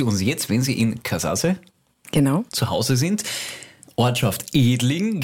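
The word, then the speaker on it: Kazaze: Der treue Bonus wartet immer noch bei uns und in der Zwischenzeit hat sich jemand gemeldet, hat uns aufgeklärt, dass es nicht Katzasse ist, wie wir es ausgesprochen haben, aber wir waren uns da einfach nicht sicher. Haben wir nicht gewusst, wir haben wieder dazugelernt. Wird jetzt richtig ausgesprochen? – Katzasse, und ist natürlich die slowenische Bezeichnung von Edling.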